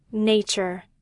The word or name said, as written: nature